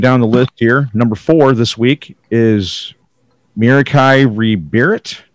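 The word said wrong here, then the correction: Merieke Ri Berit: Did I say that right? – Mariki. – Mariki, yeah. – Mariki Rebirit.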